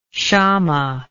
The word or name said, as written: shama